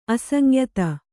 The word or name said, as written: ಅಸಂಯುತ